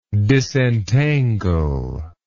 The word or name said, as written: disentangler